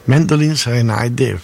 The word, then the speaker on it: Mandolin Serenade: Mandolin Serenade sarebbe la pronuncia più giusta.